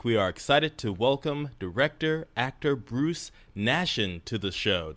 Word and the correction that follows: Nachsin: Like Jackson with an N. – Jackson. Nachsin.